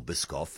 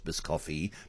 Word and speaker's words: Biscoff: So I'd never heard of Biscoffy or Biscoff, and I thought it was Biscoffy, but it's Biscoff.